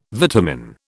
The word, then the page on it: vitamin: British English